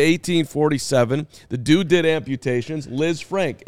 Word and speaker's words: Lisfranc: Napoleon's army, it's definitely Liz Frank. I'm telling you, it's Liz Frank.